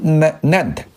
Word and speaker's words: NAND: 这个英文的俗这个简称呢叫 NAND 啊，我们就读成 NAND 啊。这个做 NAND 的快闪记忆体的市场里面，全球的唯一的中国的参加者，就很多都是外国，日本啊等等这些，美国啊等等啊，这个中国的台湾也是很强大的。那么中国的参与者呢，就是这个 NAND 啊，就是这个长江存储。